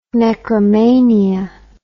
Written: necromania

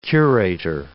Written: curator

